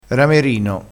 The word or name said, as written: ramerino